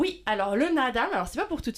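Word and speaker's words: Naadam: J'ai entendu parler, alors je ne sais pas trop comment on prononce, le nadam. – Nadam, oui. – Nadam. – Ah oui, on dit, comme en fait il y a deux A, on dit nadam.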